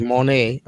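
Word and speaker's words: Moné: Mercedes Monet is how she wants it pronounced, I've heard.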